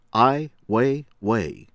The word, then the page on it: Ai Weiwei: American English; from China